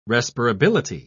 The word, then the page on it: respirability: British English